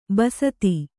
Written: ಬಸ್ತಿ

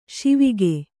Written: ಶಿವಕ

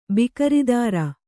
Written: ಬಿಕರಿದಾರ

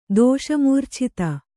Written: ದೋಷಮೂರ್ಛಿತ

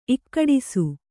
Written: ಇಕ್ಕಡಿಸು

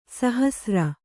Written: ಸಾಹಸ್ರ

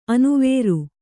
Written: ಅನುವರ

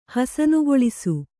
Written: ಹಸನುಗೊಳಿಸು

